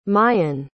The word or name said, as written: mayan